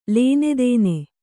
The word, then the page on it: ಲೇನೆದೇನೆ: Kannada